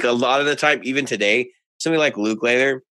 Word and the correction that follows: Lukather: I can never say his name right. – Lukather.